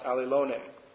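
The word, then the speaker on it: allelone (ἀλλήλων): And again, it's pronounced allelone.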